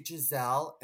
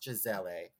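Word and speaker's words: Gisele: She wasn't nice to me because I met her at Fashion Week and I said, oh my God, are you Giselle? And she was getting, people were like primping her and she's like, it's pronounced Giselle.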